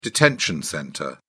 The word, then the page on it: detention centre: British English